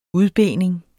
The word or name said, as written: udbening